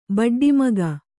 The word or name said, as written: ಬಡ್ಡಿಮಗ